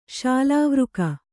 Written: ಸಾಲಾವೃಕ

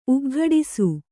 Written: ಉಗ್ಘಡಿಸು

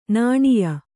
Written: ನಾಣ್ಯ

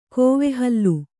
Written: ಕೋವೆಹಲ್ಲು

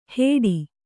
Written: ಹೇಡಿ

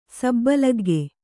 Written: ಸಬ್ಬಲಗ್ಗೆ

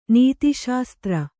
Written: ನೀತಿಶಾಸ್ತ್ರ

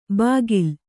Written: ಬಾಗಿಲ

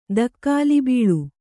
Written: ತಗುಲುಬೀಳು

